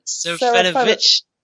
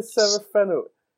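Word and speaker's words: Serafinowicz: His voice, there's a few people whose voices, Peter Serafinovich, his name? – Why can't I say his name? – Serafinovich. – Peter Serafinovich.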